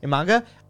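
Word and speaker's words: Imanaga: Now, if I'm seeing, I'm struggling to pronounce his name, Imanga with the Cubs, is that how you say it? – Imanga.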